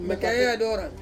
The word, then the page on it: mekae adorang: Ansus